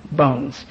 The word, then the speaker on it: Bowen: The last name is B-O-W-E-N, but they pronounce it Bones.